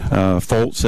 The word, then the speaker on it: Folse: Foltz, F-O-L-S-E.